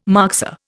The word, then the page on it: moxa: Indian